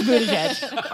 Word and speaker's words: Buttigieg: I think it's pronounced Buttigieg. – It's not. It's Buttigieg.